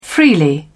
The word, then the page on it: freely: British English